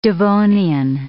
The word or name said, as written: Devonian